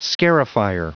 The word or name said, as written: scarifier